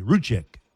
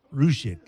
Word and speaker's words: Ruzic: And right before the game starts, about half an hour, they find out that the big 7-footer, the Ruiz kid, Ruzic, well, crap, how would you say that? R-U-Z-I-C, Ruchik. Ruchik, yeah. The Croatian found out that afternoon that he was eligible to play.